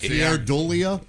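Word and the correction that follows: Keir Dullea: No, Kier Dulay. – Oh, that's how you say it? – Yeah.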